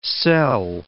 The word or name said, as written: selle